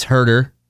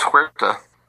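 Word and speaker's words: Huerter: I'm right. It's Herter. – It is? – Yeah. Herter? Unless every NBA podcast that I listen to, and I listen to like two or three, mispronounce it. – I thought it was Huerta.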